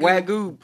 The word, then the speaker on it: Wagyu: Is that how you say it? Wagyu? – Wagyu, prime beef.